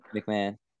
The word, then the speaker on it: McMahon: McMahon.